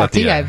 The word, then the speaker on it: Plateia: How do I say this? Am I saying Plateia? Very yeah. good. Yeah. – It's almost Spanish.